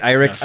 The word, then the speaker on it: IRIX: Irix? Yeah. I-R-I-X? – Uh-huh.